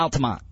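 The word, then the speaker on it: Altamonte: Yeah, Altamont. – All right, Altamont.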